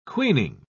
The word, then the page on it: queening: British English